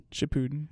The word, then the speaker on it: Shippuden: Don't watch like part one and think and like kind of like give up on it, because I don't even know how you pronounce it correctly. Is it Shippuden or is it Shippuden? – Shippuden.